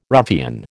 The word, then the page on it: ruffian: American English